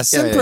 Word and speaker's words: sempre: The first musical word today is sempre. – I took Spanish. – Yeah, this is going to throw you off then. – I. – It seems like it should be I, but it's Italian, in fact.